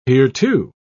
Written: hereto

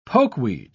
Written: pokeweed

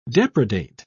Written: depredate

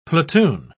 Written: platoon

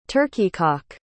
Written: turkey-cock

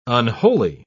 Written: unholy

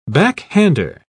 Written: backhander

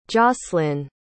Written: jocelin

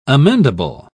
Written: amendable

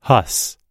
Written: huss

